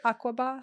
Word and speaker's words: Aqaba: Aqaba? – Yeah.